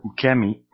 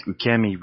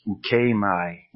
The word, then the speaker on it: ukemi: Because if I did that with ukemi, we'd probably end up with something like ukemai, ukemai. But it is not that way.